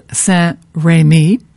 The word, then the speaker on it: Saint-Rémy: There is a community quite close to Three Pines, and it's called Saint-Rémy. That's how it's pronounced.